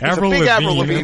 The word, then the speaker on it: Avril Lavigne: Ask me who, April Lavigne is. I don't, okay? – Priorities straight. Avril Lavigne. At least pronounce the name right.